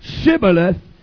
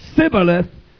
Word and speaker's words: Shibboleth: If he said, nay, then said they unto him, say now, Shibboleth. He said, Sibboleth, for he could not frame to pronounce it right.